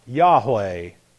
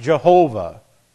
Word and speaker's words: Jehovah: And it should not be, by the way, pronounced Yahweh. It should be pronounced Jehovah.